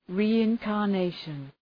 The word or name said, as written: reincarnation